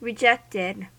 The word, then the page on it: rejected: American English